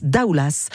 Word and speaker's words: Daoulas: Vous avez choisi, alors ni mer ni montagne, mais expo, direction l'abbaye de Doulas. On prononce Daoulas. Le S, Daoulas.